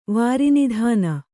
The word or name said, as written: ವಾರಿನಿಧಾನ